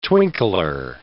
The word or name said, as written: twinkler